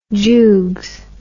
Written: jougs

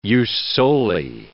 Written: jus soli

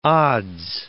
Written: odds

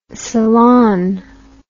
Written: celan

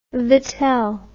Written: vittel

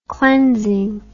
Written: cleansing

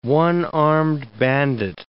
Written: one-armed bandit

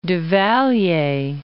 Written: Duvalier

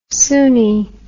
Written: suni

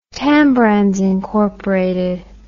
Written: Tambrands Inc

